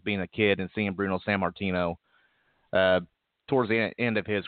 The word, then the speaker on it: Bruno Sammartino: Well, you know, with guys like that pass on, it's Bruno Sammartino is how you pronounce his name.